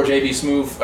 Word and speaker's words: JB Smoove: He should have got JB Smoove before JB Smoove.